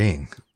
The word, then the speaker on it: Ing: And I'm not the king, but my name is Edward Ing. King rhymes with Ing.